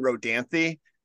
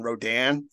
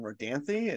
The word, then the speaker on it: Rodanthe: It's I think it's Knights and Rodanthe, but we pronounce it Knights and Rodan for like 20 minutes on the podcast, and then I was like, I think we're not pronouncing this right.